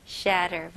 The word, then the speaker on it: shatter: 네, 대부분의 이제 미국식 발음으로 중간에 이렇게 TT가 있으면 이렇게 가벼운 D 발음이 주로 나와요. 예를 들어서 better, 그렇죠? butter, okay? matter 이런 식으로 똑같이 여기서 shatter보다는 shatter 해볼게요. shatter, shatter, very good.